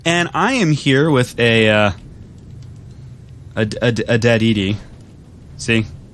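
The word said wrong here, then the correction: ED-E: Eddie, sorry.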